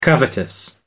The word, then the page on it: covetous: American English